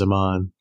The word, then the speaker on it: Aman: You can follow me on Twitter at Amon the Wargamer. Amon is spelled A-M-A-N. And on Longshanks at Amon Kusro. I'm in all the major Discords as well as Jesse. My name there is Amon, Dark Jedi.